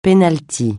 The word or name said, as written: penalty